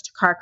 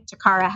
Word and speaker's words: Toccara: And so to find me online, you can actually search Toccara Karizma everywhere. You spell it very weird. Karizma is K-A-R-I-Z-M-A. Toccara has two C's in it.